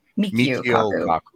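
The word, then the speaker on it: Michio Kaku: Mikio Kaku.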